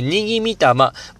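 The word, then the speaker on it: 和魂: で、これ神様にはですね、にぎみたま、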